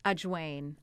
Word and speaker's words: ajwain: And then I add ajwain seeds, also known as carom seeds. They're the tiniest seeds in the Indian compendium. They're so good. They taste like thyme. – Say the name again. – Ajwain.